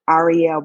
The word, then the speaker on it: Ariel: And my email address is, this is all lowercase, Ariel Brown. And my first name is spelled A-R-I-E-L.